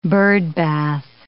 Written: birdbath

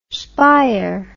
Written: spyer

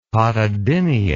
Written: pododynia

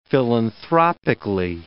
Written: philanthropically

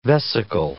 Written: vesicle

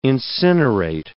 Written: incinerate

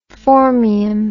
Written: phormium

